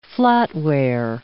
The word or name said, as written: flatware